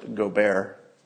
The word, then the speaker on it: Gobert: Somebody says he's pronounced Gobert, which sounds correct.